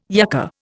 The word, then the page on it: yucca: Indian